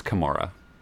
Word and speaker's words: Kamara: Is it Alvin Kamara or Kamara? – Yeah, I believe the correct pronunciation is Kamara.